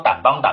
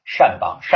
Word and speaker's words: 掸邦: 我前面老是说掸邦掸邦啊，就念一直是这样念，其实正字是念善啊，善邦善族。